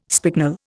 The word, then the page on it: spicknel: Indian